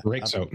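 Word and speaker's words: Röyksopp: Rake Soap. Yeah. Rake Soap. That's how you pronounce. I think there's like a Norwegian or Swedish or something like that. Apparently that's how you pronounce it.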